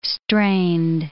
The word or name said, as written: strained